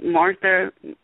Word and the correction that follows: Mothra: Well, how do you say it? – Mothra.